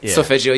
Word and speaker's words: solfeggio: Tell everybody about the esophageal frequencies. Did I even say that right? – Yeah, solfeggio, yeah.